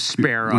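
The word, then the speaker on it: Spero: Sparrow. What is that? Yeah, we pronounce it like the bird, sparrow.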